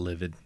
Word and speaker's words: LVLD: I call it Livid. That's it's pronounced. – It's LVLD.